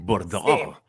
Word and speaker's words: Bordeaux: Bordeaux. Sí. Qué curioso cómo se escribe Bordeaux y cómo se pronuncia, ¿cierto? – Sí, porque está la X y yo siempre me olvidaba y ahora mi hermana es muy francesa porque ha vivido allí.